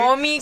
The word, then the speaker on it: Omicron: Omicron, if I am saying it correctly. – Omicron.